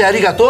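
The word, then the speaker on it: arigato (ありがとう): Eu falei certo? É arigato? Tá certo, muito certo.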